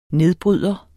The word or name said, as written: nedbryder